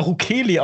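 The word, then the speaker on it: Rukeli: Ruckerli? – Ja, ich dachte, früher habe ich immer Ruckerli ausgesprochen, wie von Kurt Weil, dieses Jukali, was ja auch der Max Rabe singt. Jukali. Aber ich glaube, er heißt Ruckerli.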